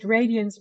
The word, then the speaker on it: Radiance: I would pronounce it Radiance myself, but it's not a real world. So there's some leeway in how to pronounce it.